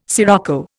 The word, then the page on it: scirocco: Indian